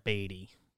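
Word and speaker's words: Baty: My understanding is it's Brett Beatty. Because I've seen people tweet that this should be pronounced Batty because baseball's plays yeah. play with bats.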